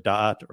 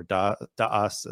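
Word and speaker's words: da'at (דעת): It's getting that gnosis, which he calls das, the sort of portmanteau or this kind of combination of knowledge, which is the Hebrew word da'at or da'as in the Ashkenazic pronunciation, and dat, the word for religion, probably, or and maybe even just the word this, das, in German.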